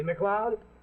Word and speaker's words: MacLeod: See, McLeod? Sounds like Cloud, but it's spelled M-A-C-L-E-O-D.